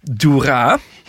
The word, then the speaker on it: Doera: Spreek uit als hoera met een D. Doera. – Dat soort dingen zetten ze erbij, zodat je dat dan niet. Dat staat dan nee, dat weet ik. Nou ja. Maar ik heb toch heel erg de neiging om te zeggen Doera. Maar. Ik heb het nog nagevraagd en het zijn nee, de klemtoon op de A.